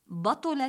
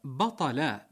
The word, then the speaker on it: بطل: أيها الأكارم، من الأخطاء الشائعة الأخرى المتعلقة بالتلفظ الصحيح للأفعال قولهم إذا حضر الماء بطل التيمم بفتح الباء وضم الطاء، لكن أحبتنا هذا أيضا خطأ. الصواب بطل بفتح الباء والطاء، أي فسد وسقط حكمه،